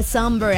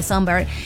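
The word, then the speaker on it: Sombr: Contigo hasta las 10, Matra, y quiero contarte que un dato súper random sobre Summer es que su nombre artístico se pronuncia como la palabra inglesa somber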